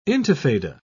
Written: intifada

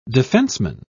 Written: defenseman

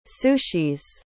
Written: sushis

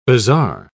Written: bazaar

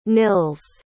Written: nils

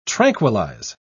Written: tranquillize

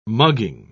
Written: mugging

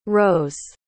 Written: rows

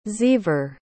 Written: xever

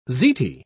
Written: ziti

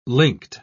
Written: linked

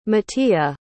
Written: matia

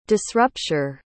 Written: disrupture

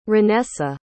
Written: renessa

